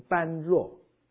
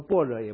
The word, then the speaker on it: 般若: 当然，在佛经里面，除了这个 Bodhi 这个字以外，另外一个字常用一个字，你可以念成般若，般若哈。其实据我所知，佛教正式的念法，如果我没有念错，是念成般若，破音字哈。我们中文普通念是般若嘛，可他们佛教徒不念般若的，般若。